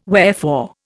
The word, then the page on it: wherefore: Indian